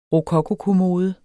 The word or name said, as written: rokokokommode